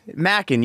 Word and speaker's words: Mackin: Mackin. You nailed it. Like mac and cheese.